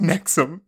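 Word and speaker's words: NXIVM: So Max arrest is related to alleged involvement in an organization called Nixivn. – Yeah. – Pronounced Nexium.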